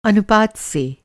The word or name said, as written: अनुपात